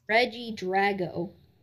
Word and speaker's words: Regidrago: Regidragi, I think it's pronounced. – Regidrago. – Oh, wow. – Or Regidrago.